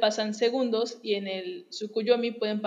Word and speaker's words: Tsukuyomi: Bueno, Tsukuyomi, que es el dios de la luna, no, no sé si lo estoy pronunciando exactamente bien. – ¿Tsukuyomi? Sí, Tsukuyomi sí.